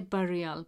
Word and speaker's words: burial: It looks like burial, B-U-R-I-A-L. And I say burial, but if you check it, pronunciation is burial site.